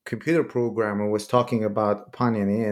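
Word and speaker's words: Pāṇini: It was fascinating that you mentioned Panini. You said that we put the stress on the first syllable, right?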